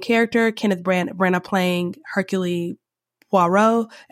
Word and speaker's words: Hercule Poirot: Hercule, there's a French way of pronouncing it, Poirot.